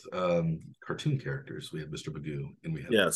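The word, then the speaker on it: Mcgough: They've also brought out Alex McGuff. – Magoo is, I believe, how they say it. It looks like McGuff, perhaps, but it's, yes. But it's Magoo. – Magoo.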